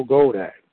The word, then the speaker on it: Goldack: Goldack. Michael Goldack. Rhymes with Kodak. It's more like a Goldack.